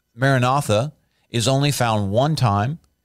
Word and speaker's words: Maranatha: That's kind of our English way of saying it, to officially pronounce it in the Aramaic. It kind of phonetically sounds a little bit different, but that's what most people know it as, Maranatha.